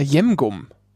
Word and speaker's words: Jemgum: Und ich habe schon des Öfteren Leute das komplett falsch aussprechen hören. Und zwar sehr, sehr englisch. Und ich glaube, die haben dann immer gedacht, das wäre irgendwie so eine Kaugummi oder so. Und zwar Jemgum.